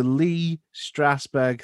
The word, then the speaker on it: Lee Strasberg: You went to the Lee Strasberg Theatre and Film Institute. Hope I didn't butcher that name. – No, you got it.